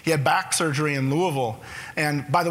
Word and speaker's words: Louisville: I have a friend who actually says the way you pronounce Louisville, you have to say it like you've got a Louisville Slugger stuck in your mouth. You know, Louisville, you kind of give up on all those vowels.